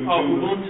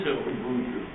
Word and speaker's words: Ubuntu: Ubuntu. – Ubuntu.